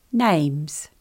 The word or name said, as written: names